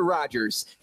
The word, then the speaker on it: Rogers: Andrew Rodgers here with you.